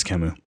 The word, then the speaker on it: Camus: I don't know if I'm saying his name right. It's French. – Yeah, that's Camus.